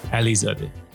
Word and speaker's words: Alizadeh: Alizadeh. – Alizadeh. – Yeah. – Is that close enough? – Close. – Yeah. Okay. – Put a lot of emphasis on the A in the middle, but Alizadeh.